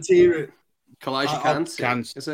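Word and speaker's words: Calijah Kancey: But it's, is it Kalijah Kansi? Is that how you say it? Or Kansi? – Oh, Kan. Kalijah Kansi. – That's Kansi. Him.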